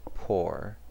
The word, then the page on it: pore: American English